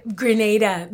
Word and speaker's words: Grenada: Well, I, Grenada, because um, I and I make the distinction in pronunciation because I think of Gra- Granada as Granada in Spain,